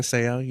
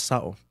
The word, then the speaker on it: Sa'o: Well, I don't mind sayo. You know, it's a compliment for within the Polynesian community, but the proper way is sao.